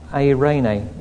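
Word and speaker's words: Eirene (Εἰρήνη): Peace, the Greek word is Irene, or Eirene is how they pronounce it.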